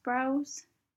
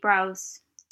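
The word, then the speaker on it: Brause: Another nib that I purchased was a Browse, I think it's pronounced Browse. It's B-R-A-U-S-E.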